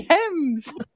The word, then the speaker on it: Thames: the Thames.